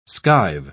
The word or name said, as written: skive